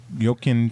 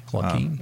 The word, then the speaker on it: Joaquin: Joaquin, Texas. Okay. – Joaquin, Texas.